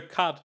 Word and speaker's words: quatre: It's menage a quatre. It's cat with an R.